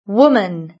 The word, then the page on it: woman: British English